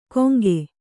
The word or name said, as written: ಗೊಂಕೆ